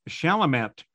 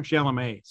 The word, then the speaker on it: Chalamet: And also Timothy Chalamet, who you might know from Dune. Yeah, did I say his name wrong? Chalamet, sorry. – Yeah, Chalamet.